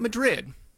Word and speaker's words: Madrid: I had to edit myself because there's a town in Iowa spelled M-A-D-R-I-D, and it's not Madrid, not like the town in Spain. It's Madrid, and people get really mad if you mispronounce that.